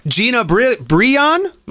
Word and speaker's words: Breon: Gina Breon? – Oh, that was a nice pronunciation.